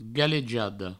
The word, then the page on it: galejada: galejado